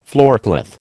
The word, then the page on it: floorcloth: American English